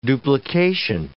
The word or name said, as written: duplication